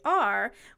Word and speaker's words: Gwenhwyfar: And lastly, the origin of mine own name, Gwenhwyvar, which is Gwenhwyvar, which was the precursor to Jennifer.